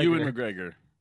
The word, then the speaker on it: Ewan McGregor: Ewan McGregor.